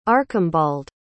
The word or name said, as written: archimbald